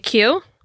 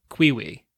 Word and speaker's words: queue: So I used to play that game all the time, and the word that I never understood was the word Q-U-E-U-E. – Like a Q? – Like a Q, yeah. For some reason, it was the first time I'd ever seen that word. In my head, I always pronounced it Quiwi. – Oh, like a Q. You said Quiwi. – Finally realized, oh, I've been saying that wrong a really long time.